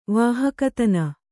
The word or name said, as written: ವಾಹಕತನ